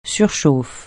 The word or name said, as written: surchauffe